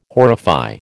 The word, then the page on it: horrify: American English